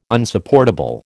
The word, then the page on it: Unsupportable: American English